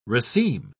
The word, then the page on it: raceme: British English